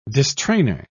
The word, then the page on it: distrainor: British English